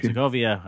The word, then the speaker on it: Sokovia: He replays Slovakia. And then he replays Sokovia. – I can't pronounce things.